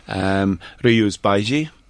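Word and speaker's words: Rías Baixas: Ryus Baiji is how you pronounce the name, the area that it comes from.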